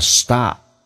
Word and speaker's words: Stop: Stop ST 发成 SD，要浊化，打开口腔啊，同时 P 不用发音，那么因为后面是一个那么辅音，这叫略音，叫 Stop。